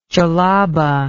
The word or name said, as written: jellaba